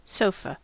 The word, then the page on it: sofa: American English